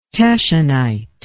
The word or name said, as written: teschenite